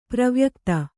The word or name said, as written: ಪ್ರವ್ಯಕ್ತ